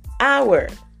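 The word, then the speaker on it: hour: Our. So the H is silent.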